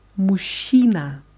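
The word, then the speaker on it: мужчина: Ja, und ein Mann heißt auf Russisch Muschina. – Muschina. – Ja, das Wort wird geschrieben mit J und Che in der Mitte wird aber zusammen so wie ausgesprochen, Muschina.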